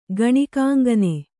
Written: ಗಣಿಕಾಂಗನೆ

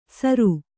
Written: ಸರು

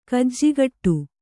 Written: ಗಿಜಿಗುಟ್ಟು

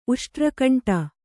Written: ಉಷ್ಟ್ರಕಂಟ

